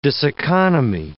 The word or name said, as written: diseconomy